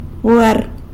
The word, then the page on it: buarr: sanlucasquiavini